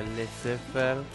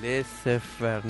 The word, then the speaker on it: Laissez-faire: Pero ahora, bueno, en las acetillas aclaramos que se pronuncia Laissez-faire. – Sí. – Y bueno, nada, cada cual que nos pregunta, que nos preguntan siempre, sí, le decimos, no, mira, es Laissez-faire, no, Laissez-faire o...